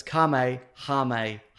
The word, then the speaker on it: Kamehameha: So it's Kamehameha.